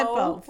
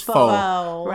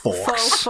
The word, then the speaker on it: faux: We said faux. – It's faux. It's faux. – Right. – Fawkes. – We're wearing our faux fur. – I said Fawkes because that's how it's spelled.